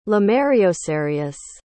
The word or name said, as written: lemaireocereus